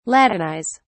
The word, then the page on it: latinize: British English